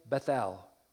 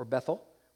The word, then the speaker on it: Bethel: Then he poured oil over it, and he named that place Bethel, or Bethel, whichever way you would like to pronounce it, which means house of God, although it was previously called Luz.